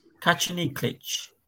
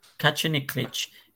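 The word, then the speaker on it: Kačaniklić: They had Alexander Kachaniklic. – Kachaniklic. – Kachaniklic. – Say it right. – Kachaniklic. – That's it. – Kachaniklic.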